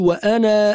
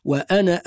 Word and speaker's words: وأنا: وأهم خطأ يقع فيه كثير من الناس، بعض الناس يقول وأنا أول، شوفوا هذه الألف في كلمة وأنا فوقها في صفر مستطيل، هذا الصفر المستطيل معناه أن هذه الألف لا تنطق عند الوصل، تكون محذوفة عند الوصل، فنقول وأنا أول المسلمين. ونلقاكم على خير حال، وأشكركم على حسن استماعكم، والسلام عليكم ورحمة الله وبركاته.